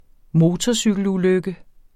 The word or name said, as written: motorcykelulykke